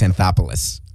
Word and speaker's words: Anthopoulos: It's Alex Anthopoulos. – Yeah, that sounds better. – As in the Acropolis, which also happens to be in Greece.